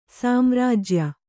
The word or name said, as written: ಸಾಮ್ರಾಜ್ಯ